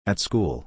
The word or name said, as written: At school